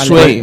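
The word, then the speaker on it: Zwei: Sway, no sé la pronunciación alemana. Para mí es Sway, es Marco Sueiro, tío. Me sale solo. – Es pronunciación alemana. – Sway. Sí, es verdad. Es, me parece que es dos en alemán, ¿no?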